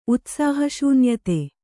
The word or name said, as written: ಉತ್ಸಾಹಶೂನ್ಯತೆ